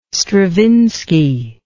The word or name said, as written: Stravinsky